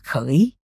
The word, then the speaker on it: khỉ: khỉ đọc là khởi khỉ không